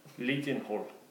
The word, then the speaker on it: Leadenhall: Leadenhall is how it's pronounced.